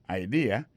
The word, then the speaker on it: idea: T-H-A. T, apostrophe S, good, G-O-O-D pour good, ça veut dire bon, idea, c'est I-D-E-A, ça veut dire idée, that's a good idea. Alors, accent vient tomber dans la deuxième syllabe là, one idea.